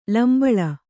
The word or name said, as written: ಲಂಬಳ